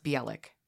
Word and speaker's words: Bielek: I think it's pronounced Bielek. It's B-I-E-L-E-K.